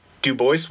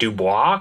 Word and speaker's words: Dubois: You would think looking at it that it would maybe be like Dubois because it's French, but they're very firm on the notion that it is pronounced Du Bois.